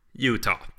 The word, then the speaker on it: Utah: Nej, om du uttalar ute som ute inne. – Utah, precis.